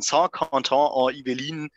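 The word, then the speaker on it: Saint-Quentin-en-Yvelines: In Saint-Quentin en Yvelines in Frankreich müsste das sein.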